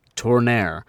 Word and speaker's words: Tourneur: And again, I double-checked the pronunciation there, listeners. And that's how the French say it. You'll have to forgive my Jersey accent, but it's Tourneur.